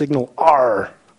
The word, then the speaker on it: SignalR: Just to make sure you know how to pronounce it, it's SignalR, like the pirates. So SignalR.